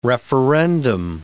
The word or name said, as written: referendum